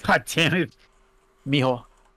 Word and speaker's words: mijo: It sounded like, if anything, it actually sounded like Miho, which the whitest way ever would be Jesus Mijo. – Oh, that's true. Because they spell it starts with a J in Spanish.